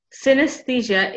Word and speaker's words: synesthesia: Yes, so today we're going to talk about synesthesia.